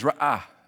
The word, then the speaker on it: ra'ah: In the Old Testament, the word for neighbor is ra'ah. And you got to get that little break in there if you're going to say it right.